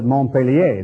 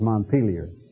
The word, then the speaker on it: Montpelier: I guess the French pronounced it Montpellier, didn't they? But it's Montpelier.